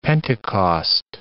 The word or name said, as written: Pentecost